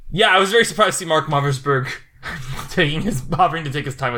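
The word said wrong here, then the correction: Mothersbaugh: I think it's Mother's Ba. – Is it? Have I been mispronouncing his name this entire time? Because I've been talking about him as a good composer. My big hot take... Yeah, it is Mother's Ba, you're right.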